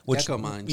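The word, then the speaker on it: Gécamines: He is the chairman of it's a company called, I don't know how to pronounce this, Gekko Mines. – Yeah, that's perfect pronunciation. – It's, yeah. – That is the Congo's state-owned mining company. – Oh, okay. Gekko Mines.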